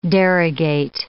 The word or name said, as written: derogate